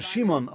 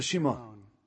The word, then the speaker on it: Shimon: Auf Hebräisch wird er Shimon ausgesprochen. Sagen Sie alle Shimon.